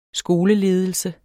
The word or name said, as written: skoleledelse